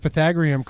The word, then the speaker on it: Pythagorean: I'm very surprised you pronounced Pythagorean correctly. – Thank you. A lot of people don't do that.